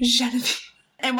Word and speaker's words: Genevieve: Genevieve or Genevieve? – No. Soft G. – Genevieve. And what I need to do is I need to straighten my hair and middle part it, and I'll be Genevieve.